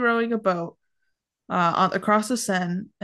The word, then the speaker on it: Seine: I think it's the Seine. – The Seine.